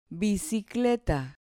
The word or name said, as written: bicicleta